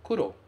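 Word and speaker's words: kuro (黒): A pronúncia desse kanji é kuro.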